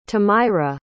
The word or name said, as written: tamyra